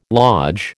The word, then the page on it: lodge: American English